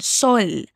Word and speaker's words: sol: Otra vez, y cuida la pronunciación de la L en sol.